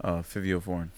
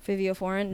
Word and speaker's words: Fivio Foreign: Fivio Foreign. – Fivio Foreign.